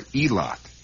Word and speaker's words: Eilat: is a tiny settlement called Eilat. E-I-L-A-T. Or Eilat, if you prefer it that way. Generally pronounced by the people in that area as Eilat.